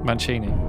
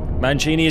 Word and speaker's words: Mancini: Mancini. – Mancini is correct.